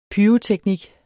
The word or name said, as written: pyroteknik